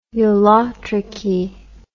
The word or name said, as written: ulotrichy